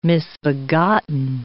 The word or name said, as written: misbegotten